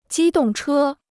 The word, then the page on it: 机动车: jī dòng chē